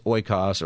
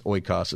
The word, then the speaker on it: oikos: The word there is oikos or ekos, or different ways to say it. In ancient Greek, most people think it's oikos is how they might have pronounced it back then.